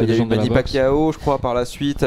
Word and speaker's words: Pacquiao: Pacquiao. – Ouais, j'arrive Pacquiao.